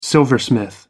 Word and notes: silversmith: American English

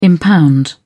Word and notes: impound: British English

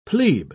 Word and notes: plebe: British English

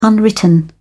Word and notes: unwritten: British English

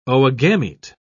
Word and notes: oogamete: British English

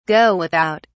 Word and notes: go without: British English

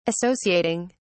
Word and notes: associating: British English

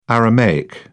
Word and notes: aramaic: British English

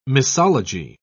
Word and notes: misology: British English

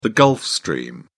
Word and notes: gulf stream: British English

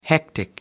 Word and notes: hectic: American English

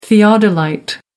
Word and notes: theodolite: American English

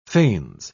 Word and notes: fains: British English

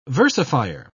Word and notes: versifier: British English

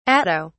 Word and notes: atto: British English

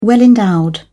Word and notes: well-endowed: British English

